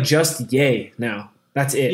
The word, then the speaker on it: Ye: Yeah, he changed his name to Yee West. – Facebook also to Yee or Yay? – Y-E. – Yeah, so Yay. He pronounces it Yay, I'm pretty sure.